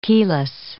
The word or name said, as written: keyless